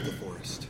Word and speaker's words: DeForest: What was it like working with DeForest? – Oh, DeForest Kelly?